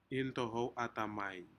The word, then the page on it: intohou atami: Aneityum